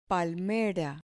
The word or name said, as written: palmera